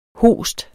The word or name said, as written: host